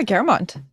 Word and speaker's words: Garamond: Yeah, Garamond.